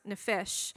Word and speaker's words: nephesh: And in the Old Testament, the word that is used is a Hebrew word which is pronounced nephesh.